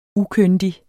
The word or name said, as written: ukyndig